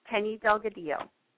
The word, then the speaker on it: Penny Delgadillo: It's Penny Delgadillo.